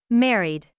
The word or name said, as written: married